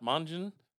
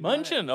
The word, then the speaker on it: Mongeon: And Scott Mongeon. Did I say that right? I didn't say that. I must have messed it up again. – No, Mongeon. – Mongeon.